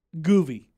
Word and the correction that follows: guvy: I'm saying it wrong. Govy. – Govy? – Govy. Okay, G-U-V-Y. Govy.